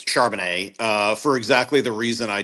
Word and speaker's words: Charbonnet: Give me the pronunciation guide. – Charbonnet. – Thank you very much. That's what I thought.